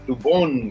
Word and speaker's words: Dubón: Exactamente, bien, entonces Mauricio Dubón, este jugador que pertenecía a los gigantes de San Francisco, lo recuerdo por ahí, Mauricio Dubón, con acento en la O, exacto.